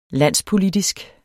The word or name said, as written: landspolitisk